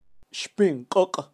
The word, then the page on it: xpinkok: Kaqchikel